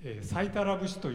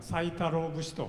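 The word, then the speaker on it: 斎太郎節: それではですね、第一ステージがもう最後になってしまいますけれども、サイタラ節というのを歌います。プログラムでですね、サイタロ節と書いてございますけれども、これはサイタラと、読むそうですね。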